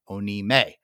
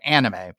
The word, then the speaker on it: anime: He has used the most unique English pronunciation of anime I have ever heard in my entire life. Onime. – It's got to be some form of just maybe that's a Japanese accent. Pronunciation. No. So I always called it anime, and all the people I know who are hardcore into anime call it anime.